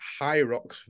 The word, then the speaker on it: Hyrox: Is it called Hyrox? – Pronounced Hyrox without the D, but yeah, who fucking cares?